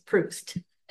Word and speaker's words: Proust: So then he talks about it's kind of a story here of how he encountered, is it Proust? Is that how you pronounce? Okay, it's Proust.